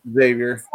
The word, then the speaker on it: Xavier: Xavier, is that how you pronounce it? – Xavier, yes.